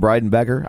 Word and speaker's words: Breidenbecker: Ryan Breidenbecker. I know I'm saying that right because I said it wrong for like 4 weeks and we finally figured it out.